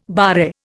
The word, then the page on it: barre: Indian